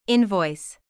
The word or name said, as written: invoice